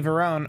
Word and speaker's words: Varon: Whereas Lisa Marie Varon, or um, pretty sure that's how you pronounce that, uh, yeah.